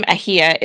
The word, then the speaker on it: Ahia: And my last name, Ahia, is my married name, and I'm not quite sure what it means. But when you pronounce it, make sure you say it as if you're about to sneeze.